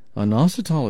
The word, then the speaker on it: inositol: It's called inositol. Let me spell it for you. I-N-O-S-I-T-O-L.